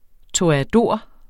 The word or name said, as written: TOREADORERNES